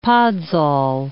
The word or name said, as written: podsol